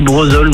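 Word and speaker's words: Brezolles: Bah, ça s'écrit Brezol, mais on dit Brezol, nous, généralement.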